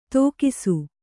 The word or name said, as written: ತೋಕಿಸು